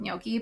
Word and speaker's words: gnocchi: I don't like pasta, but like you can never go wrong with gnocchi, but like I know that Trader Joe's gnocchi is good. Why am I saying it so weird? Is that how you say it? – That's how you say it.